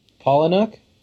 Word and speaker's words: Palahniuk: I always say his name wrong. But based on the novel, a very faithful adaptation. In fact, Palahniuk, I'll just go with Ethan's enunciation.